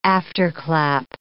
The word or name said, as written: afterclap